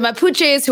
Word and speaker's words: Mapuches: I don't know if I'm saying this right. It's the Mapuches? – Yeah. – Okay. So the Mapuches, who are the largest indigenous community in Chile, have reportedly staged this rebellion.